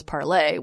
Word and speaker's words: Parler: Yeah, so this was the – yeah, Parler is – I'm still going to continue to call it Parler because I'm not French, but it is apparently the original, like the intended pronunciation of this platform was Parlay, which – tells you, for one thing, that it's a stupid fucking name for a platform.